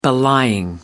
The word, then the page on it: belying: American English